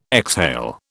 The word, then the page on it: exhale: British English